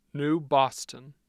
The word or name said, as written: new boston